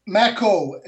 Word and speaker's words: Macko: I'm glad to be here, and it's Macko. It rhymes with tobacco.